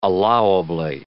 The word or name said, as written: allowably